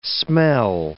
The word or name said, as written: smell